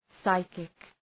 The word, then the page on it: psychic: ‘saıkık